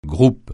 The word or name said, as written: groupe